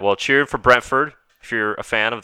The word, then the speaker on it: Brentford: Was it Brentwood? Am I saying it right? – Brentford.